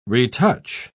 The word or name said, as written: retouch